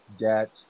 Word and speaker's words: debt: It's similar to the words doubt, debt, and dumb. The B is silent because there's no being in those words. So for me, when I say N-O-T...